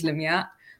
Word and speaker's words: Lamia: So, but the correct Arabic way is Lamia.